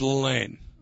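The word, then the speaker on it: Lalanne: I just pronounced his name Jack Lalonde. Is that Lalane or Lalonde, the great I always said it was Lalane. – Lalane. – Lalane, yeah.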